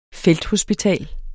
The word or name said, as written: felthospital